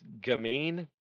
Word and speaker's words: gamine: G-A-M-I-N-E. Gamine. It's like playful. I don't know. It's like, it's kind of a weird description. I don't know why. – Is a slim, often boyish, elegant young woman who is or is perceived to be mischievous, teasing, or sexually appealing. It's a French word.